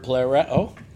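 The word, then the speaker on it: Plereo (πληρόω): If you have BLB or some tool like that, you want to look up the word plereo, you can. Plereo would be the pronunciation.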